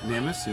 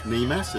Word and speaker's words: NEMAS: So that's why we pronounce it NEMIS instead of NEMAS, as many people do. So you can always tell a local because they call us NEMIS.